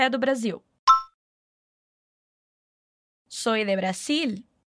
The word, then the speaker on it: Brasil: Muito cuidado com a pronúncia do nome do nosso país, hein? O som de z, z, de abelha, no espanhol não existe. A gente vai pronunciar como na palavrinha acima, si. E o nosso L do final de Brasil vai ficar preso com a sua língua lá atrás dos dentes da frente.